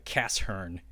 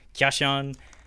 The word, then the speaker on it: Kyashan: It's called Kyashan. Kashern, if you're pronouncing it that way. But it's Kyashan.